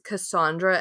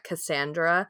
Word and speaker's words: Cassandra: it is pronounced Cassandra and not Cassandra.